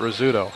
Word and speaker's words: Rzeszutko: You know, you're talking about Rizzuto, Brad, and you were fortunate, we were talking in the pregame, but that's how it's pronounced. His name is spelled R-Z-E-S-Z-U-T-K-O, and they call it Rizzuto.